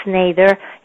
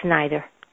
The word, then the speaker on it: Snyder: You know, in Spanish, we pronounce the vowels as you read them. So in Mexico, my last name is pronounced Snyder. In America, Snyder.